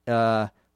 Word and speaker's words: whew: The that little sound that I made is spelled W-H-E-W, and it's an exclamation, if you will. It's a sound that we make in English to express relief. I'm so glad this day is over. That's how I make the sound. Other native speakers may make it differently, but when you see it in a book or see it in writing, it's spelled W-H-E-W.